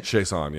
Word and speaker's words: Chiasson: I mean, Macy Chiasson, I believe is the pronunciation. – Chiasson, yes.